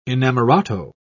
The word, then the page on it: inamorato: British English